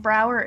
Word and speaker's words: Brouwer: And Brouwer is Dutch, so it's spelled differently than you might think.